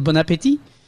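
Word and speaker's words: bon appétit: Well, they don't say bon appetit because we don't pronounce the T at the end of bon appetit.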